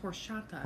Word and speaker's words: horchata: And you get the hugest, it's pronounced horchata. – Horchata. – You don't pronounce it. The lady at this restaurant told me that's how you say it.